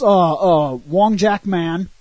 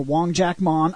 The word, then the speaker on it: Wong Jack Man: This monk comes, and he's, of course, Wong Jack Man, or Wong Jack Mon. I don't know how you want to say it. – I've heard it say Mon. – Many times I've heard people pronounce his name as Mon. – That's how I pronounce it. – The movie calls him Man all the time.